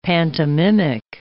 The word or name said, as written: pantomimic